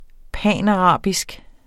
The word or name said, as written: panarabisk